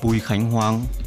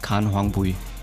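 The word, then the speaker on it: Bùi Khánh Hoàng: Also mein Name ist Bui Kang Huang wenn man so die auf die vietnamesische Art ausspricht. Aber auf Deutsch heißt Khan Huang Bui.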